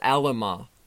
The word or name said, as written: Alema